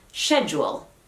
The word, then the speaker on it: schedule: Ahora, supuestamente hay personas que lo pronuncian schedule, pero yo nunca las he conocido. Lo más normal y lo más aceptado en Estados Unidos es schedule. Schedule. Schedule.